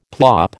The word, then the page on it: plop: American English